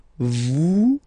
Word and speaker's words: vous: Vous êtes. Same thing here. Notice that final S is not pronounced.